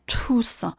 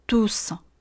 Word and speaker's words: douce: 另外，清辅音在遇见元音之后变成了不送气的清辅音，所以 douce 这个词不念 tous。而念成一个介于送气和浊音之间的一个音，类似于我们中文中的发音方式 douce douce。